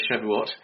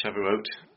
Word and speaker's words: Shavuot: Apparently it rhymes with a blue oat, so I'll try not to say Shavuot, it's Shavuot.